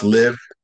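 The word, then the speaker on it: Liv.e: Right after that, there's this chick that I found who is very interesting, and her name is Liv, but it's spelled L-I-V. Period E, L-I-V dot E, but it's pronounced Live.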